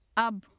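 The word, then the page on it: اب: Urdu; 「アブ」; [əb]; ab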